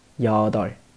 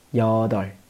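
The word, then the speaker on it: 여덟: 下一个， 여 덟 시 간 啊， 여 덟 시 간 啊，八个小时。那么小时前面的话呢是要用固有词的，所以这里的八应该读成 여 덟 여 덟 여 덟 시 간 이 상 啊，以上呢？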